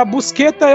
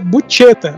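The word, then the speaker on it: Buscetta: O Tommaso Busqueta ou é, como era chamado né, na mídia brasileira nos anos 80, é, que a pronúncia correta é Bucheta, mas vai falar isso na televisão.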